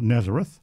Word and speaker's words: Nazareth: It's spelt the same as the town in Israel, Nazareth. But the BBC guide, which we have in the studio here, says Nazare, and the AI, the worthy AI Google reference, says Nazarek.